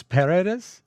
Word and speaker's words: Paredes: This is Las Paredes Tienen Oídos. – Paradise. – That's P-A-R-E-D-E-S.